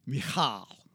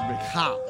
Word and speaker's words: Michal: So the question is, what action of David offended his wife, Michal. I'll pronounce her name correctly. – Michael? – No, Michal. – Michael. – It's not Michael. It's not spelt like Michael. It's Michal.